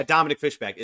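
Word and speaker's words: Dominique Fishback: Oh, yeah, Dominic Fishback isn't. Yes, so I did say her name right.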